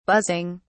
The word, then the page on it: buzzing: British English